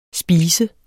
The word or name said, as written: spise